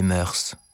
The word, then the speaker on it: mœurs: La musique adoucit les mœurs. On ne doit pas prononcer le S, d'après le dictionnaire.